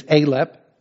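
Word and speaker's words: Aleph: So I looked them up, and it says that the pronunciation of the first letter is Alep, A-L-E-P.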